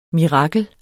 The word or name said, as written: mirakel